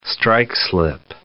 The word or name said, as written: strike-slip